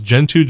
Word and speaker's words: Gentoo: The Gentoo, the G in Gentoo is soft, so it's Gentoo Gilligix.